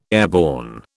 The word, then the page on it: airborne: British English